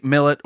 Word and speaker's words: Millett: Now, I always want to say Millet or Millett because I'm thinking of this two L's, one T. So what's the correct pronunciation? Am I doing it right? – It's actually Millet,